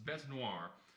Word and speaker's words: bête noire: Elon Musk's bête noire.